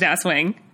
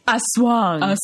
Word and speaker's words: Aswang: It's not pronounced Aswang. It's Aswang.